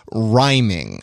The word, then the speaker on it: riming: a process called riming. R-I-M-I-N-G.